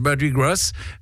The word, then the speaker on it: Mardi Gras: C'est marrant, ça se prononce comme en français. Ça pourrait être Mardi Gras, mais voilà, ça s'appelle Girl, I've Got News for You.